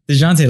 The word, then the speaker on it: Dejounte: DeJounte, my bad. DeJounte Murray. – DeJounte. – DeJounte, like the mustard, man. – You fucking Mexican. – Yeah, no, I like it. I'm going to call him DeJounte from now on.